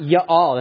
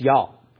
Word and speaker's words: y'all: But it's not y'all. It's not two syllables. It's one syllable. It's y'all. Okay, it's just y'all.